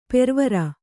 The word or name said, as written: ಪೊರವಾರ್